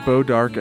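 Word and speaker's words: Bois d'Arc: So that's Bo Dark, and we should emphasize that that is spelled BOISD'ARC.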